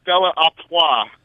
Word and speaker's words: Stella Artois: That beer is pronounced Stella Artois. I don't know what he said last week, but a Stella Artois. It's French.